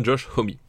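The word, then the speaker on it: Homme: ça se disait homie parce qu'il y avait justement des francophones, des Québécois à la con qui lui demandaient comment ça se prononçait et ça se prononce bien homie. Et d'ailleurs, bah, si on peut en attester, il y a le podcast qu'il anime sur la radio de iTunes, hein, le Beats One, qui s'appelle Alligator Hour, et il prononce bien Josh Homie.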